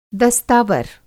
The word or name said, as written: दस्तावर